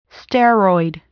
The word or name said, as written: steroid